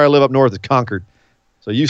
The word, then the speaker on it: Concord: We don't say it like you, Northern California. We say Concord.